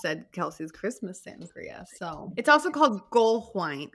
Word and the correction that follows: Glühwein: Glühwein. – Glühwein. – See, I got that. – That's how you say it in German.